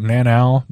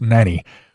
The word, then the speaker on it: Nannau: It's actually spelled N-A-N-N-A-U, so it looks like nanow, but pronounced nanny.